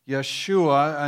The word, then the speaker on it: Yeshua: Where it really gets complicated is that this Joshua is the same name as our Jesus, but we understand the Greek pronunciation of it. Yeshua, in other words, the J isn't loud in most of these pronunciations.